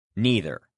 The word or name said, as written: neither